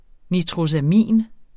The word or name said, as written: nitrosamin